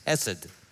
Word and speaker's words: chesed: The Hebrew word is chesed. Chesed. Say that with me. Chesed, chesed, chesed. That's the way it's pronounced.